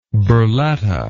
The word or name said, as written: burletta